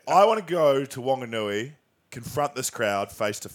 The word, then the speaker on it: Whanganui: Whanganui, oh, because there's the H. – Whanganui.